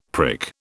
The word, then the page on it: prick: British English